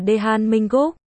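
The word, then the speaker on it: Dae-han Minh Gúc (대한민국): TL, Hàn Quốc trong tiếng Hàn đọc là Dae-han Minh Gúc.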